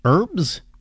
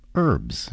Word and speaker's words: Herbs: We just played music from the Roots and Herbs. Herbs? Herbs. Yeah, you don't pronounce the H, do you?